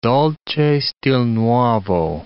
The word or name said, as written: dolce stil nuovo